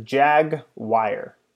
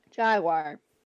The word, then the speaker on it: Jaguar: She said she pronounces it as Jag-wire. – Thank you. – That's weird. – You're weird. Jaguar.